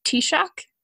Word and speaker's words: Taoiseach: So like a mild example of this is like we were just talking about the head of Ireland, the Taoiseach, is that how you pronounce it? Yes, yeah.